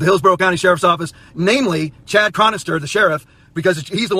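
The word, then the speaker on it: Chad Chronister: The sheriff, Chad Cronister, I believe is how you pronounce his name, Chad Cronister, and his cohort with the state attorney's office.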